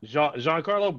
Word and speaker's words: Giancarlo: Is it Giancarlo or is it? – John. – Giancarlo, bro.